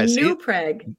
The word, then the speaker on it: New Prague: New Prague with the emphasis on the new. – See? – Is how the locals pronounce it.